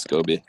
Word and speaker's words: SCOBY: Isn't it pronounced SCOBY? Like dooby dooby doo? Am I saying that wrong? – Scobie? I think it's Scobie. – I believe it's Scobie.